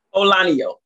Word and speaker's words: Olaniyan: Please pronounce your last name before I butcher it. – Olaniyan. So you can just say Marshaun O.